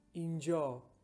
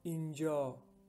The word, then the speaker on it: inja: To say here in Persian, we say Inja, Inja. To say there in Persian, we say Anja, or you can say Unja.